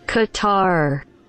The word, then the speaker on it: Qatar: Qatar. – I said it right. I said Qatar.